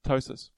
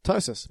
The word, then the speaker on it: ptosis: Ptosis. – Ptosis. Yeah. Ptosis. Yeah. – Silent P.